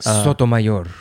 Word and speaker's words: Sotomayor: Sotomayor.